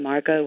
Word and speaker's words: Margot: A lot of people like to put a T at the end of Margot, so it kind of looks like Margot. So, no, it's just plain Margot with an O.